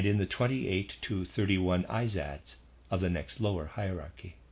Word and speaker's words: Izads: The Persians called the beings of the rank below the Amshaspads, Izads. Readers aside, that's how I'm going to pronounce that. I-Z-A-D-S, Izads.